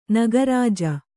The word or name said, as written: ನಾಗರಜ್ಜು